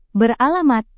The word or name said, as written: beralamat